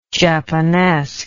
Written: japanesque

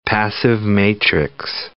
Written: passive-matrix

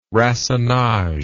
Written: racinage

